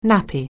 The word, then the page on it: nappy: anglais